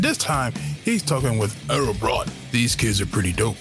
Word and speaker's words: Årabrot: Hi, this is Tom from Ghost Cult, and we're delighted to be joined by Kjetil and Karin from Årabrot.